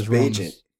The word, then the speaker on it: Bagent: But we know it's Bagent because it says it on his bio. It says pronounced like agent.